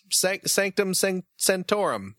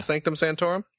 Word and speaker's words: Sanctum Sanctorum: Sanctum Sanctorum. – Mm-hmm. – Am I saying that correctly? – Yeah, the Sanctum Sanctorum.